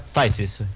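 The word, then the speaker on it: Phthisis: É tipo uma palavra muito impronunciável, mas tipo, pra quem quiser saber, se pronuncia Thysis. O PH lá são meramente ilustrativos, eles não servem pra nada.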